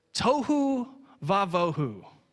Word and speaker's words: tohu vavohu: The word for formless and empty is tohu vavohu. Can we say that together? Tohu vavohu. It rhymes, right? there's a rhyme to it.